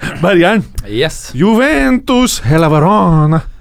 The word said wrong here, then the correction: Hellas Verona: Hella Verona, faktisk. Hellas. Eh, kutter man ikke desen? Nei. Uttalen. – Men, men Hellas Verona.